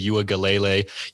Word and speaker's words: Uiagalelei: I do not like their quarterback, DJ Uiagalelei. Yes, yes, you just heard me pronounce that name correctly.